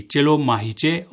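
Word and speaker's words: چلو ماہیچہ: جی، چلو ماہیچے، اور اس میں چے کے آخر میں چھوٹی استعمال ہوئی ہے، آپ اسے بڑی یے کے ساتھ تلفظ کیجئے، چلو ماہیچے.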